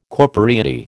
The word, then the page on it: corporeity: American English